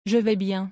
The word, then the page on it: Je vais bien: French